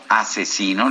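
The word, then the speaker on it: Aczino: mejor conocido como Asesino, lo escribe AZZ no, a c z asesino, así se pronuncia, bicampeón del Red Bull Batalla de los Gallos Internacional, se puso a disposición del titular del Ejecutivo para defenderlo.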